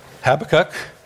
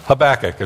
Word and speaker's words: Habakkuk: Some people say Habakkuk, and some people say Habakkuk, and Habakkuk is how I'm going to say it today.